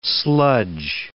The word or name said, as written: sludge